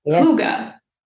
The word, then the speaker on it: Hygge: It's called Hygge, which is spelled as H-Y-G-G-E.